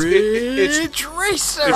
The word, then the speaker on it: Ridge Racer: Wait, is that how you're supposed to pronounce it? – Yeah, it's Ridge Racer.